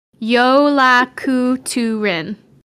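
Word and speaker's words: Jólakötturinn: They also own a cat who's known as the Yule Cat, or his real name, Yolakuturin, which is spelt J-O with a little thing over it, L-A-K-O with two dots, T-T-U-R-I-N-N. Yolakuturin, the Yule Cat.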